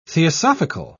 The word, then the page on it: theosophical: British English